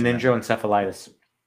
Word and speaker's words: meningoencephalitis: Meningococcalophilus? I don't think it's meningitis, but it's one of those itises you don't fucking want. MENINGOENCEPHALITIS. I'm not good at pronouncing meningoencephalitis.